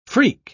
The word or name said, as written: freak